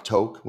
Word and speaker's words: Tok: the town of Toke, which is spelled T-O-K.